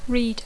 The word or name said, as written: read